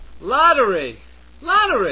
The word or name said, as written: lottery